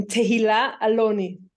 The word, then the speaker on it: Tehilla Aloni: Tehilla Aloni.